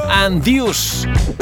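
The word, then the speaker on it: Anduze: Andius. Se escribe Anduce con Z, por si lo quieres encontrar, porque es un artistazo.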